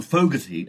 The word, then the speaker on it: Fogerty: Yeah, it's Creedence Clearwater Revival frontman's main songwriter, John Fogerty, as he himself pronounced his name in the interview, interviewed by Adam Sweeting.